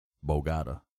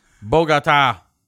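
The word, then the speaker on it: Bogotá: We went to Bogota. Bogota. It's so hard for me to remember to say that right. Bogota. Bogota.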